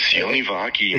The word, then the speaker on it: Sione Vaki: He's put up some stats so far, but last week it was, is it Sione Vaki? Is that how you pronounce it? – Yes, Sione Vaki, man.